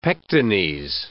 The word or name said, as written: pectines